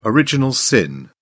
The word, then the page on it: original sin: British English